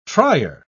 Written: trier

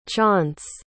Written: chaunce

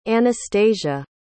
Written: Anastacia

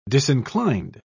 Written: disinclined

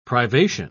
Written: privation

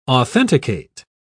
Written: authenticate

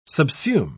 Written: subsume

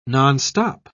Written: nonstop

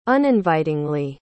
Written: uninvitingly

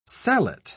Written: sallet

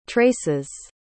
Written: traces

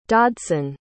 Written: dodson

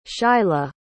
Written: shilah

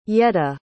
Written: yedda

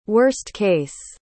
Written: worst-case

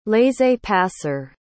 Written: laissez-passer

